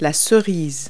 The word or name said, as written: cherry